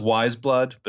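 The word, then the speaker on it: Weyes Blood: I had to go watch an interview to see how it's pronounced. It's pronounced Wiseblood, but it's not Wiseblood the band.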